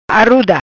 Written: Aruda

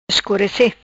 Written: Escurece

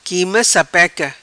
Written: quima sapeca

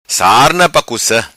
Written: Sarna Pa Cuca

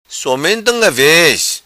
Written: Somente Unga Vez

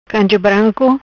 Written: Canje Branco

